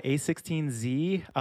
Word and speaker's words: a16z: I don't know how to pronounce the name of the company because it said A16Z. Is that am I pronouncing it right? – Yeah, A16Z, um, mm-hmm.